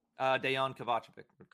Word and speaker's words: Dejan Kovacevic: Yes, and for what it is worth, Dejan? That's how you pronounce it? – Dejan Kovacevic. – Kovacevic, yeah.